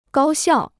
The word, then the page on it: 高效: gāo xiào